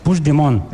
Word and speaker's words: Puigdemont: Me has preguntado antes, Hassan, ¿cómo se dice este nombre? – Sí, voy a intentar. – No, Puigdemont. – Puigdemont. Vale. – Puigdemont. – Eso es. – Sí, lo dices como francés, así.